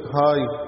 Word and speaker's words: high: انت عارفين في اللغات مش شرط ان الكلمة تتقري حسب الحروف، يعني مثلا في اللغة الانجليزي ممكن كلمة نطق هاي وهي الاسبلينج بتاعها HIGH. تخيل لو الكلمة دي HIGH محدش نطقها قدامي وانا شفتها هنطقها هغ، HIGH هغ. طب ليه قلناها هاي؟ لان اللي قبلينا نطقوها هاي.